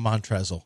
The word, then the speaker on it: Montrezl: He calls him Montrezl. I know you're. – Okay, spell it. Spell his name. – Montrez. – L. What's that spell to you? – Montrez. – Montrezl. – The L is silent.